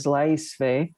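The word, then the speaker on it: Laisvė: So, but one way to say it is Laisve, and that's in Lithuanian. But even Lithuanian people pronounce it differently in different regions.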